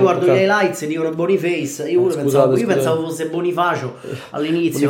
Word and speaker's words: Boniface: Ti correggo la pronuncia, sentendo telecronaca, Boniface.